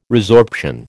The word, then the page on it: resorption: American English